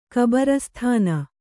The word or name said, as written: ಕಬರಸ್ತಾನ